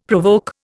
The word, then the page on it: provoke: Indian